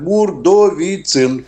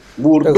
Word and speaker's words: Бурдовицын: Виктор Степанович, я правильно сделал ударение в вашей фамилии? Скажите, пожалуйста. Э, немножко неправильно. Бурдовицын. Бурдовицын.